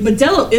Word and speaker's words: Modelo: Like, Modelo is Modelo.